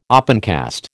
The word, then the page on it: opencast: American English